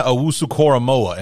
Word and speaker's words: Owusu-Koramoah: In the second round, Jeremiah Owusu-Koramoah.